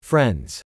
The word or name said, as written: Friends